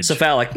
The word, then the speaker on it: Cephalic: Am I saying that correctly? – Cephalic Carnage. – Cephalic. Yeah, Cephalic. See, that's where the whole East Coast Midwest thing starts coming in. Is where do you start where do you put the pronunciation on your vowels? – I thought you were about to say pronunciating.